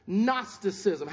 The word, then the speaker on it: Gnosticism: Gnosticism. How you spell that is G-N-O-S-T-I-C-I-S-M.